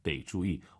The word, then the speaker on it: 得: 得 올라가면 안 되죠. 뒤에가 사성이니까 반삼성 得注意.